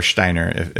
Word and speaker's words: Steiner: And here's the deal that Steiner, a lot of people don't know about, or Steiner, if you pronounce it properly.